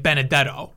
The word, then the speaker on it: Benedetto: It can't be pronounced however they pronounce it in Italy. It's got to be like Benedetto. That's his last name.